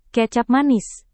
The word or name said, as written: kecap manis